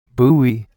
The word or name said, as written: buoy